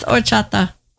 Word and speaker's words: horchata: But it's actually pronounced like or. – Also, the H is silent. – It's horchata. Yep.